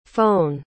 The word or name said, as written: fone